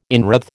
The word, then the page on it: inwreathe: American English